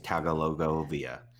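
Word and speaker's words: Tagovailoa: Well, I would have taken Burrow over to it, and the correct pronunciation is Tagalogovia.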